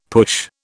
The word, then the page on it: push: British English